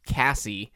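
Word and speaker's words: Cazzie: I guess it's Cassie, but it's spelled with Z's.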